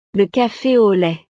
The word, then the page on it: le cafe au lait: frances